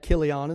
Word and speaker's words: Chilion: Chilion." And some people pronounce that Kilion, and that's okay, but I see C-H-I-L-I, that's Chili to me.